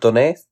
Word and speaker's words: Donetsk: So there's a town called Donetsk. Donetsk. Donetsk. Donetsk. – Okay. – It's spelled D O N E T S K, and that is a part of Ukraine that was taken over by Ru- pro Russian mm.